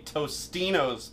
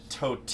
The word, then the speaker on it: Totino's: They say Tostino's Pizza Bites when it's Totino's Pizza Bites.